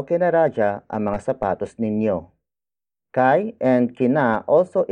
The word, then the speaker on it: kay: Ako, spelled as A-K-O. It means I, in focus form. After that, it's kai. Kai. Kai. Kai is spelled as K-A-Y.